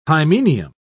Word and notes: hymenium: British English

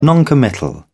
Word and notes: noncommittal: British English

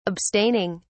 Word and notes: abstaining: British English

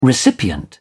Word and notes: recipient: British English

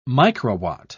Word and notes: microwatt: British English

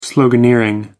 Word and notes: sloganeering: American English

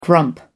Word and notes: grump: American English

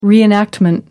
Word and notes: reenact: American English

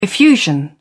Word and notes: effusion: British English

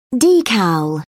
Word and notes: decal: British English

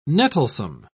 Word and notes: nettlesome: British English

